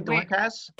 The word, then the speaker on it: Dorcas: Dorcas? Am I saying that right, Dorcas? – Yes.